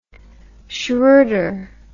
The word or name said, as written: schroeder